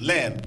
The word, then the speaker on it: leb: It's actually L-E-B, but it's pronounced leb, a lev. L-A-V, it's like pronounced like lev, but to spell it is L-E-B.